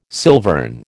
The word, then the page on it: silvern: American English